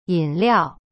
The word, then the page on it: 饮料: yin liao